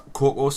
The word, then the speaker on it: cacao: We found one called Now, I don't know how to rightly pronounce this, because it doesn't look like cocoa. Is cocoa, or is it cacao? – Cacao. Nah, I'd say cocoa.